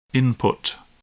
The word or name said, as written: input